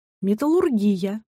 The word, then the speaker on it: металлургия: Правильно произносить «металлургия», «индустрия», «терапия», но при этом «гастрономия», «кулинария», «логопедия».